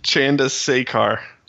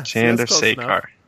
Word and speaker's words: Chandrasekhar: Chandrasekhar? What? How do you say it? – Chandrasekhar. – Damn it. – Think Sandra. Sekhar. – Chandrasekhar.